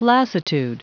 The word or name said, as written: lassitude